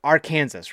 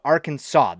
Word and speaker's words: Arkansas: It's like Arkansas, right? It's not Arkansas. There's no uh, W. There's nothing in the English language that pronounces Arkansas, Arkansas like saw.